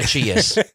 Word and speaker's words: Aegeus: So Theseus, an Athenian, and his father, Aegeus, I hope I pronounce all these right. – Aegeus. – Huh? – Aegeus. – Aegeus? – I studied mythology.